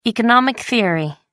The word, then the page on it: economic theory: American English